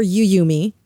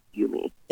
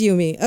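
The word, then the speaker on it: Yuumi: Is it Yumi or Yuyumi? – I've heard so many things. I've heard people call it Yummy. I believe the correct pronunciation is Yumi. – It is Yumi.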